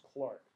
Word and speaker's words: clerk: Now, it's spelled like clerk, but pronounced Clark. Notice it rhymes with Mark.